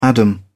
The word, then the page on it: adam: British English